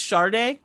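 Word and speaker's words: Sade: Shade. – You know her name is actually supposed to be pronounced Sharday, like with an R? – What?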